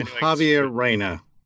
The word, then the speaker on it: Javier Reyna: I'm Javier Reyna.